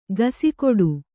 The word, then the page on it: ಗಸಿಕೊಡು: gasikoḍu